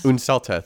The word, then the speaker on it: Unselta: What was her name? I couldn't even like pronounce it. – Ursella, in my head. – I think. – Unselta. – Unselta, thank you.